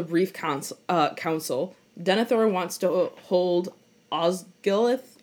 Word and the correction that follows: Osgiliath: Osgiliath, since he knows that's where Sauron will hit hard.